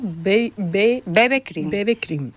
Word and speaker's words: BB Cream: Lo he dicho bien, ¿no? – Bebe cream, eso es.